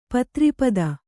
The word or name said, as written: ಪತ್ರಿಪದ